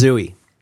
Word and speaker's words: Zooey: It's pronounced Zooey.